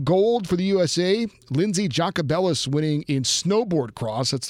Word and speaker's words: Jacobellis: Jacobellis. – Yeah, Jacobellis.